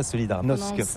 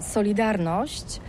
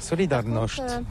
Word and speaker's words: Solidarność: Alors solidarité, en français on dit solidarnosc, mais ici à Gdansk on ne prononce pas solidarnosc. – Solidarność.